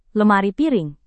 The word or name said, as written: lemari piring